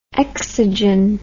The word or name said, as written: exogen